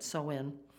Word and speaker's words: Samhain: And the way they pronounce it, S-O-W-I-N, they don't spell it that way, but Samhain, reminds me of sowing seed. That's why I think that's probably the right pronunciation.